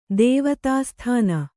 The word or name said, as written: ದೇವತಾಸ್ಥಾನ